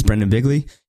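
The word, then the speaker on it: Brendon Bigley: Anyway, my name is Brendan Bigley.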